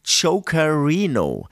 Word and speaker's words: Chokerino: Dann hier muss ich jetzt aufpassen, da wurde ich jetzt angeschrieben, ich habe es falsch ausgesprochen, ist natürlich Chokerino.